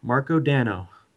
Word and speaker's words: Marko Dano: Marko Marko Dano. It rhymes.